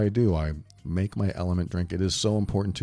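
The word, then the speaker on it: LMNT: So I'm here to tell you about Element. It's L-M-N-T. It's pronounced Element.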